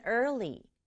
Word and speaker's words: early: 첫 번째는 early. early예요. 발음이 좀 어렵죠. E-A-R 만나서 er, 그 다음에 L-Y 만나서 li. 그래서 혀가 슉슉 이동을 해야 돼요.